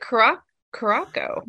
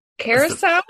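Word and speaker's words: curaçao: I always pronounced it Caraco, but is that not how you pronounce it? – The Curacao? – Curacao? – That's the fancy pronunciation.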